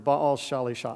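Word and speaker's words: Baal Shalishah: Now, I want you to focus in on that word, Baal Shalisha.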